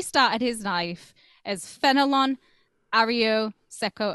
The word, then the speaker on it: Arroyo Seco: Arroyo Seco Park? – That's pretty quick, Arroyo Seco.